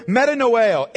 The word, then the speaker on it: metanoeo: The word repent is from the Greek word metanoeo. M-E-T-A-N-O-E-O. Metanoeo. I'll spell it again. M-E-T-A-N-O-E-O.